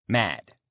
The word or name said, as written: mad